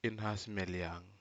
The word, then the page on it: inhas meliag: Aneityum